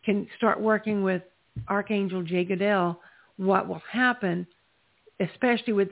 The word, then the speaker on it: Jegudiel: J-E-G-U-D-I-E-L. And it's pronounced Jay Goodell.